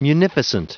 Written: munificent

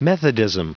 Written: methodism

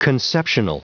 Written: conceptional

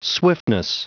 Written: swiftness

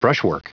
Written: brushwork

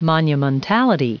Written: monumentality

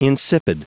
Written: insipid

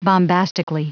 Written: bombastically

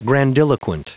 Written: grandiloquent

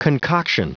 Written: concoction

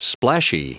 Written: splashy

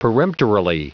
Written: peremptorily

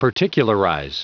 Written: particularize

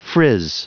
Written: frizz

